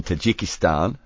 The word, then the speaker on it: Tajikistan: Now this was in Dushanbe in Tajikistan.